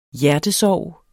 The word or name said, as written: HJERTESORGER